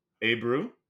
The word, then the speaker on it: Abreu: Another one was Matt Olson, Team Pisky, versus Jose Abreu. – Abreu, if you're going to pronounce the names of my players.